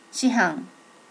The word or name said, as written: shihan